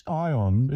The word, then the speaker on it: Aion: It's Ion. It's spelled A-I-O-N, as you say, but that's not some obscure Chinese cultural reference. It's a portmanteau, which is a word combining the spellings of and meanings of two words: AI for artificial intelligence and on for on the road. It will be mispronounced forevermore.